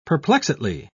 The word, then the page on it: perplexedly: British English